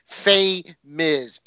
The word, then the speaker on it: Famiz: It's pronounced hashtag Famiz. FAMIZ.